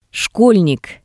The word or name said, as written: shkolnik